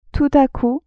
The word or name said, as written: tout a coup